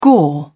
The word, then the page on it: gore: British English